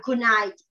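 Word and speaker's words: CANI: Phát âm là kunai, nghĩa là liên tục hoàn thiện.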